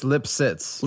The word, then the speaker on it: Lipsitz: It's Lipsitz.